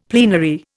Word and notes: plenary: Indian

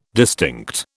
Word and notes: distinct: British English

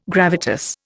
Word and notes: gravitas: Indian

